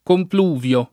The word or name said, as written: compluvio